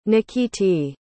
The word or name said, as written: nikiti